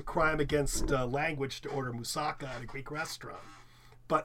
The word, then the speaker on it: moussaka: Anyway, it's in Greek, it should be pronounced moussaka, with an emphasis on the last K-A.